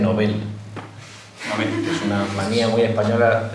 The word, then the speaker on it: Nobel: Es una palabra aguda, pues no llevaría una tilde en la O. Así que por favor, que es una Muchas gracias. Es una cruzada en la que me empeño desde hace tiempo. La pronunciación en sueco es Nobel y hay que mantener el, término.